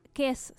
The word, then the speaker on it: Kes: Vamos a estar proyectando Kes, que es una... Kes, se escribe K-E-S.